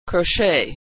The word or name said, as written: crochet